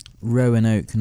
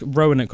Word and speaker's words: Roanoke: Roanoke, no, but I love I love the name Rowan and I love oak. – Yeah, it's Roanoke colony, so it's spelled like O... Sorry, R-O-A. – Yeah. – N-O-K-E.